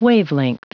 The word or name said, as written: wavelength